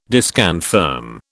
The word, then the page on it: disconfirm: British English